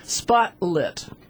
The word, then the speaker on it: Spotlit: Spotlit. Well, spotlight would have an E at the end. S-P-O-T-L-I-T. Wouldn't that be spotlit?